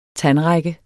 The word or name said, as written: tandrække